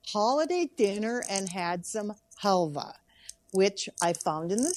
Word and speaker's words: halva: Is it halva? – We pronounced it halva.